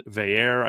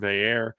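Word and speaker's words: Veilleux: They're playing another quarterback transfer in Christian Veyer, I think is how I saw them say it's Christian Veyer, which doesn't at all look like how it's spelled.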